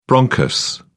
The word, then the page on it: bronchus: British English